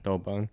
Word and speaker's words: Douban: Douban.